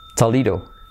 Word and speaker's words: Toledo: Toledo, Toledo, Toledo.